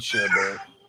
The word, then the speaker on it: sherbet: I mean, I know it's spelled sherbet. – Sherbet? – Yeah. Yep. – It's like burnt with sher in front of it.